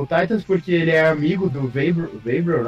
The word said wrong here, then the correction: Vrabel: né? Uhum. – Eu falei certo? – É. – Vrabel?